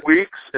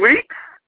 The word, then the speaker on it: Weeks: Um, John Weeks and Regis Holmes. – John, what's your say your last name again? I can't understand you when you mumble. – what? Oh my, John Weeks.